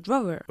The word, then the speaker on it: drawer: In a drawer. – Oh, in a drawer. – In a drawer. – Se pronuncia igual que el verbo dibujar. ¿Eh? Draw.